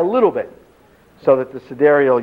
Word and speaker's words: sidereal: So we could, this is pronounced sidereal. It's spelled sidereal, but it's pronounced sidereal.